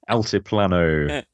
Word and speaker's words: Altiplano: Altiplano. – Altiplano.